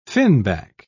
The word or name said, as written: finback